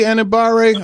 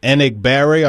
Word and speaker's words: Enagbare: Now, is he going to start and split reps with Kingsley Enagbare? I believe that's how they pronounce it. Enagbare, I believe it is how you pronounce it.